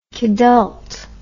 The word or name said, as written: kidult